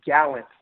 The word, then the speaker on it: Gallant: It's Gallant, like the gallant knight, the gallant horseman.